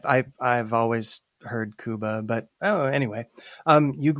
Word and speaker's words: Cuba: However, I will say that uh, he pronounces his name Cuba just like the country.